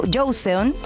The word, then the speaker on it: Joseon: Joseon. Ah, ya, ¿cómo se dice? Joseon. Ya, vamos a decirlo así.